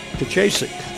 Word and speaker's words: Pijaszek: That is spelled P-I-J-A-S-Z-E-K. Pijacic.